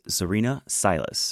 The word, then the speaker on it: Zarinah Silas: Our guest today is Zarinah Silas.